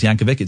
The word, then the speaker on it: Yankovic: It's actually pronounced Yankovic. It's a hard C.